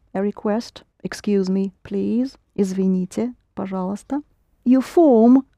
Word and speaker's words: Извините: Извините. – Извините.